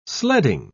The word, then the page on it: sledding: British English